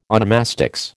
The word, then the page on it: onomastics: American English